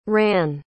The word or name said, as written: rann